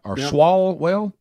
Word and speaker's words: Swalwell: Swalwell? – Yep.